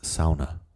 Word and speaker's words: sauna: Okay, so the same kind of thing with the sauna, and this is pronounced sauna. Sauna? Sauna. Sauna. That's how they pronounce it.